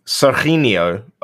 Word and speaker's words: Serginho: Well, it'd be Serginho over here, because the G for the G is H. So it'd be Serginho Dest.